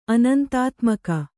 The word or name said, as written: ಅನಂತಾತ್ಮಕ